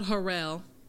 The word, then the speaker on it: Harrell: So on Facebook, it's just Lanisha Harrell. – That's it. Sharp and it's spelled Harrell. – Yeah. – For them Black folks out there. – Yeah. – H-A-R-R-E-L-L.